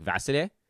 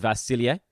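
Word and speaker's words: Vasilije: Vasile, Vasilier. If you listen to him say it, he says it so fast that it just sounds like Vasile. Uh, the slow version is Vasilier.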